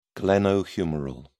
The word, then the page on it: glenohumeral: British English